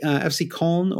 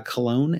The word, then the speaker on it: Köln: FC Köln, or Cologne, as we typically pronounce it in English, is where Lukas Podolski came from.